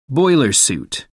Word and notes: boiler suit: American English

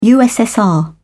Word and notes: ussr: British English

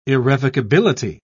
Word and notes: irrevocability: British English

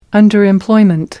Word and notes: underemployment: American English

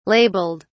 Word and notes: labeled: British English

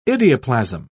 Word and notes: idioplasm: British English

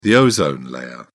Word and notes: ozone layer: British English

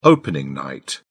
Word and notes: opening night: British English

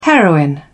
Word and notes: heroin: British English

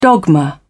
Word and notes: dogma: British English

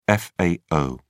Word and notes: fao: British English

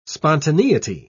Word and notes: spontaneity: British English

